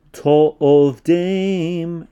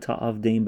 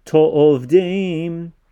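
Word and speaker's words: to'ovdeim (תעבדם): Lo ta'aseh lecha fesel, pause, v'chol timuna asher bashamayim, pause, mima'al va'asher ba'aretz mitachat va'asher ba'mayim mitachat la'aretz lo tishtachave lahem v'lo to'ovdeim. It looks like ta'avdeim, but it is pronounced to'ovdeim.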